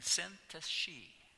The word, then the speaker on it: Syntyche: I looked the pronunciation up, and it wasn't an easy one, and I wouldn't have predicted it would have been said like that, but it's Euoda and Syntyche.